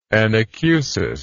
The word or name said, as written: anacusic